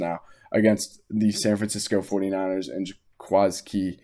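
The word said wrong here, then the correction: Jaquiski Tartt: Trying to say his name once again, it's not going well. Jaquaski Tart had that dropped interception right in the middle of the field.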